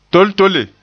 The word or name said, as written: toltole